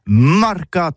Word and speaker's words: marcat: Eh bien, disons à tous, le mercat de l'Uni ou le mercat des rebelles, mais en Gascogne toulousain, c'est prononcé sur les marcats, avec un A. Marcat.